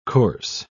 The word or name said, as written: corse